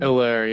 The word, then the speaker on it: Hilaire: Hilaire, yeah. The H is silent.